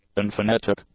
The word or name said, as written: unphonetic